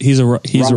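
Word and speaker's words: Roberson: He's Robertson. A robber because he steals.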